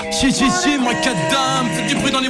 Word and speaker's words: MICADAM: Mike Adam, c'est comme ça que vous prononcez. Mike Adam, si si.